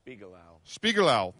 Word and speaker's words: Spiegelau: and Spiegelau. Am I saying that right? – Spiegelau.